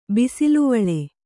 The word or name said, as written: ಬಿಸಿಲುವಳೆ